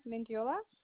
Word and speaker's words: Mendiola: Acelia Mendiola.